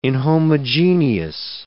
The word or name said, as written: inhomogeneous